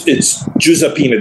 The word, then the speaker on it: Giuseppina: It's Giuseppina. There's no J in Italian. It's G-I-U, as is Giuseppe. It's spelled with a G, because there's no J in the Italian alphabet.